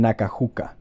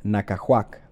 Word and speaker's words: Nacajuca: and Nacajuca, which I incorrectly pronounced as Nacajuac last episode.